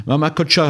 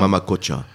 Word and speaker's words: Mama Cocha: Je crois qu'on avait même diffusé un titre à ce moment-là, parce que le nouvel album, Mama Cocha, il faut prononcer comme cela. Hein, faut avaler le L, c'est bien ça ? – Mama Cocha.